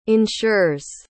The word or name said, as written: insures